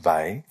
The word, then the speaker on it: Weil: She was her surname is spelled W-E-I-L, but yep. pronounced Weil.